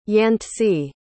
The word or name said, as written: yantse